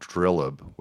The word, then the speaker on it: dilep: There's something called Drilleb, which actually would be spelled D-I-L-E-P, so you would think it would be pronounced Dilep, but this is just the problem of translating, you know, things from the native language.